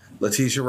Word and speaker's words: Letitia: Letitia Wright.